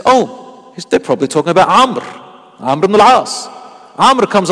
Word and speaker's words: Amr: So it's like Amru, but it's pronounced Amr. Spelt Amru, four letters, and pronounced with three letters.